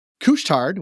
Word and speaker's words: Couche-Tard: To zoom out, Couche-Tard, which I did pronounce correctly, has also grown thanks to its willingness to experiment.